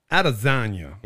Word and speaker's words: Adesanya: How off am I going to be on this pronunciation? Israel Adesanya. Israel Adesanya. – Adesanya. – Yeah. – straight like Adesanya. – Yep. – It feels like an Adesanya.